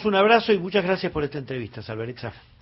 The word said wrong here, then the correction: Salvarezza: Era Roberto Salvarecha, le dicen muchos, bueno, pero hay que pronunciarlo como corresponde, ¿eh? Salvarezza,